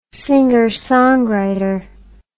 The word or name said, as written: singer songwriter